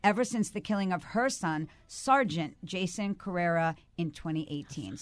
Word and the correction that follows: Hassan Carrera: So did I pronounce his name correctly? – Sergeant Hassan Carrera. – Hassan Carrera. – Yes. – Okay, I'm so sorry about that. I said that name exactly wrong, and I apologize for that because we want to honor who these people were.